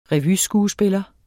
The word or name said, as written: revyskuespiller